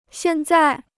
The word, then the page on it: 现在: xiàn zài